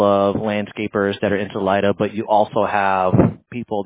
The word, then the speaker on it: Salida: So it's not just, so is it Salida? Am I saying that right? – Salida. – Salida.